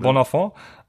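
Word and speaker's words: Bonafon: Ich habe noch einen kleinen Pick und das ist äh, Reggie äh, Bonafont. Ist das, spricht man das Französisch? – Ja, das hat sich gut angehört. Bonafont.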